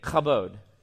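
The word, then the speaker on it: chabod (כָּבוֹד): It's pronounced this way. It's called chabod. Everybody say it with me, chabod. Chabod. You got to get that like teenage angst in there, the ch.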